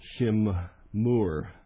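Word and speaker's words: shimmoor: The word there for observed is shimur. That's the phonetic pronunciation of the Hebrew.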